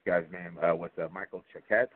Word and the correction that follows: Michel Choquette: Well, yeah, he's actually French Canadian, so he pronounces it Michel Choquette.